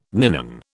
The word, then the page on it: ninon: British English